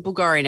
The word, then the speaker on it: Bulgari: The Bulgari. Am I saying that right? – It's a Bulgari necklace.